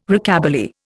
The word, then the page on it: Rockabilly: Indian